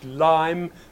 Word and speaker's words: Lympne: it's pronounced Lyme, but spelt L-Y-M-P-N-E, Lyme on the Channel Coast in Kent, and this was the preferred setting up point for all the long distance flyers.